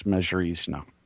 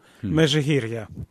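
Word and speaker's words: Mezhyhirya: And he and his wife the following weekend took me out to see Yanukovych's estate at and I always mispronounce this, Mezhirizhno. – Mezhirizhno. – There we go.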